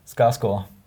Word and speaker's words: Skarsgård: Skarsgård. Achso, meinst du mit meinem, so wie. Also, wie eher, so, eher, Video so, sagen eher, so, eher mit so einem J-Laut in der Mitte. Skarsgård. Skarsgård. Skarsgård. Skarsgård.